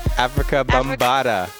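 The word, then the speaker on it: Afrika Bambaataa: Africa Bambaataa.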